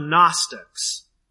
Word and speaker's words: Gnostic: Now maybe you've heard of the Gnostics and Gnosticism. Gnostic, by the way, it's not spelt the way it sounds. It's spelled G-N-O-S-T-I-C. Gnostic. Maybe you've heard of it.